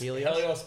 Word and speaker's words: Helios: Helios Brewing Co.